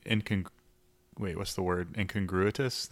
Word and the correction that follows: incongruous: That's not right. – Incongruous? – Without the T, yeah. Incongruous.